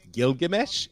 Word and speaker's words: Gilgamesh: I believe it's pronounced Gilgamesh, if you allow me to be snooty.